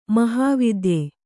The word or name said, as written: ಮಹಾವಾತ್ಯೆ